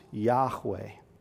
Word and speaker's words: Yahweh: It is his name, probably pronounced Yahweh, his proper name.